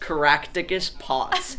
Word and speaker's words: Caractacus Potts: Caractacus Potts.